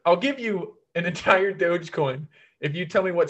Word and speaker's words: Dogecoin: I'll give you a 1/15,000th of a Dogecoin.